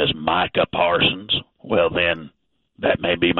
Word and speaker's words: Micah Parsons: Like, it's Micah Parsons. It's not Michael Person.